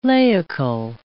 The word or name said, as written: laical